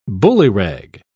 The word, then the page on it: bullyrag: British English